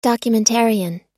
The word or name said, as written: documentarian